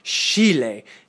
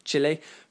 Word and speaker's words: Chile: And, you know, he won't turn down a chance to say Chile if he can, rather than Chile, because presumably in Chile, they say Chile. So if it's good enough for Chile, it is, of course, good enough for Mr. Marcotti.